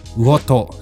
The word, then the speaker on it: 魚豊: うん、えっ、ー、と今回の課題図書は魚と魚に豊かと書いて魚とさんですね。